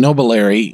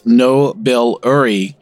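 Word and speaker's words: Nobiliary: I kept on saying Nobilary, and it's Nobilary is the correct pronunciation of this.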